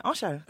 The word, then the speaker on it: Osho: Well, see, half the family say what you said, and half the family say Osho.